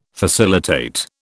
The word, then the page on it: facilitate: British English